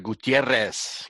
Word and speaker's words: Gutierrez: You had to give it a Gutierrez. Got to roll the R's on that, see?